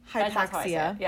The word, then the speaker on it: Hypaxia: Hypaxia.